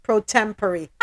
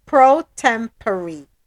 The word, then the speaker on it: pro tempore: Pro tempore. – Ah, see? Pro tempore. P-R-O-T-E-M-P-O-R-E.